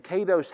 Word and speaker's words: kadosh: So our translation of the word holiness comes from this Hebrew word, and it's pronounced kadosh.